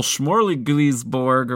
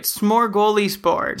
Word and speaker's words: smorgasbord: And Tuukka Rask will be discussed in our goal Smorgasbord or whatever. – You never say that right. Smorgasbord.